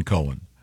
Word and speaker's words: Cohen: This that's confusing because no, not when it's spelled C-O-H-E-N. – It's definitely Cohen.